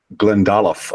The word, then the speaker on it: Glendalough: I think that's Glendalough, I think, is the more correct pronunciation.